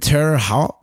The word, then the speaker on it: Terre Haute: And then in Terre Haute, that's how you say it, Indiana...